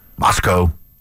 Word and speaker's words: Moscow: Remember I said it right. Moscow. Moscow. – Yeah, Moscow.